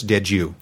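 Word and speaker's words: Dediu: But one analyst I do like is a guy named Horace Dedieu. – Uh-huh. – He's I forget. – Well pronounced, sir.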